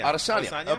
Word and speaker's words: Adesanya: Adesanya.